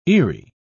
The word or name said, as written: eerie